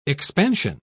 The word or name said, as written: expansion